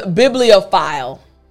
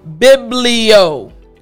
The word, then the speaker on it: bibliophile: So let me break down parts of this word to help you pronounce it properly. Again, the word is bibliophile. So B-I-B-L-I-O-P-H-I-L-E. It's a long word. Bibliophile, right? So let's focus on the first part of the word. Biblio. Biblio.